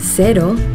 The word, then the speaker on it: cero: In Yucatan, the local Mexican surfers pronounce cero, cero. During the warm summer evenings in bars around the coast of Nayarit, you'll hear them say, cero. In New Zealand, zero alcohol Corona cero is pronounced cero. Easy, eh?